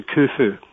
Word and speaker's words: Khufu: It's actually pronounced kh, as in loch, so it's actually Khufu as opposed to Khufu. But most people just say Khufu.